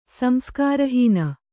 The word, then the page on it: ಸಂಸ್ಕಾರಹೀನೆ: Kannada